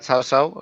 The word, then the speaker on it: Cao Cao: A ver, yo creo que lo más fácil es que lo llamemos Cao Cao, porque aunque es verdad que el nombre mucho más cercano sería Chao Chao, más cercano a la pronunciación china,